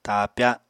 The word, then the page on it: taapya: Apatani